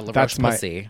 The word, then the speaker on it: La Roche-Posay: La Roche-Posay. – Oh, yeah. La Roche-Posay.